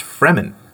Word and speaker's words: Fremen: Listening to the audiobook, it's pronounced Fremen.